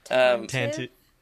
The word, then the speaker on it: Tantive: It's T-A-N-T-I-V-E. – Yeah, and there we go. – Tantive. – Skooma Joe. – Tantive. – So, Tantive? – Tantive is what it looks like. I always say Tantive.